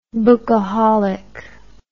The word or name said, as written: bookaholic